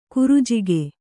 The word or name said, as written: ಕರಿಜಿಗಿ